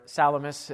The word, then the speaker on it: Salamis: Remember, they landed on the city of Salami, or Salamis, as it's better pronounced, on the eastern side of the island.